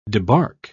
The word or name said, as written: debark